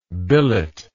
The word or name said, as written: billeter